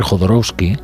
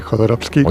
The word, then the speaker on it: Jodorowsky: iba a hacer Jodorowsky, que era un documental divertidísimo.